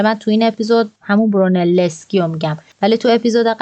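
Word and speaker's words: Brunelleschi: تلفظ صحیح اسم طراح گنبد کلیسای سانتا ماریا دل فیوره فیلیپو برونلسکی هست با یه تشدید روی ل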